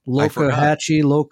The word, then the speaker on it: Loxahatchee: Loxahatchee.